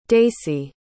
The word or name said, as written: daysie